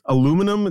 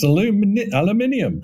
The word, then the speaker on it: aluminium: Some of the way you pronounce aluminum, it sounds like it's a part of some sort of magic trick. – It's got an I in it. It's aluminium.